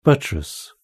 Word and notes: buttress: British English

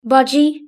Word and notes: budgie: British English

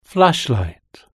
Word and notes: flashlight: British English

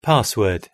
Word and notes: password: British English

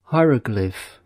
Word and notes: hieroglyph: British English

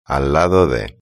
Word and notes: al lado de: Spain Spanish

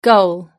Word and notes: goal: British English

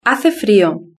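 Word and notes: hace frio: Spanish (Spain)

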